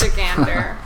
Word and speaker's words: Michigander: It's Michigander. That's the proper way to say it.